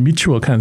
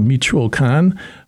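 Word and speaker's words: Michoacán: And my grandparents themselves ended up in Michigan because they're originally from Michoacan, which to the a lot of the Mexican people pronounce Michigan like Michigan and Michoacan, so it has the same hard CH sound.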